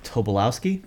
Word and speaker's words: Tobolowski: Uh, Tobolowski.